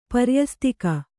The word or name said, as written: ಪರ್ಯಸ್ತಿಕೆ